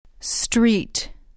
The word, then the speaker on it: street: Street. Treat. Street. – به تلفظش دقت کنید. استریت نگید. یادتون هست برای speak از تکنیک استفاده کردیم. اینجا به همین صورته. اول کلمه باید بگید اس نگید.